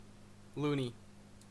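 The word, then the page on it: loony: Canadian English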